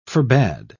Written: forbade

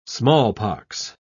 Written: smallpox

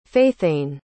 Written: faithanne

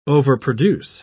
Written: overproduce

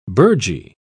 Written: burgee

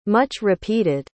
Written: much-repeated

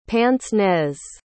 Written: pince-nez

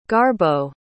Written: garbo

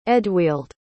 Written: eadweald

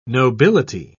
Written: nobility

